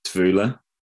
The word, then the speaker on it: Tvivler: I believe it's pronounced Tvula. – Okay. Spell it out for us there. So. – T-V-I-V-L-E-R.